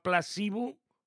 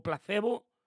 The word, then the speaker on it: Placebo: ¿Os acordáis de una banda que se llamaba Placebo o Placebo? Como queráis que pronuncie.